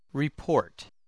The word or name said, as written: report